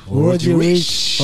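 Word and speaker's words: Roddy Ricch: Roddy Rich. J'ai bien prononcé ? – Roddy Rich.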